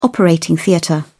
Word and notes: operating theatre: British English

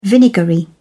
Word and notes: vinegary: British English

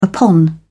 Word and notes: upon: British English

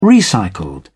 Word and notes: recycled: British English

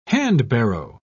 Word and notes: handbarrow: British English

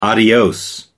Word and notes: adios: American English

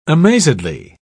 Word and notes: amazedly: British English

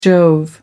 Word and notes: jove: American English

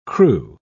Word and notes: cru: British English

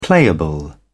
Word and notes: playable: British English

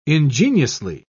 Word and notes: ingeniously: British English

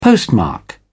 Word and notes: postmark: British English

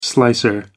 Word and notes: slicer: American English

